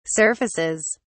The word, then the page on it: surfaces: British English